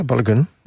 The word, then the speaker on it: Balogun: Zeg ik dat goed? Balogun is het toch? Balogun? Ja, ik zeg het goed.